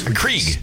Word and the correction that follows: Keurig: Keurig. – Yeah. – You don't even know how to say it.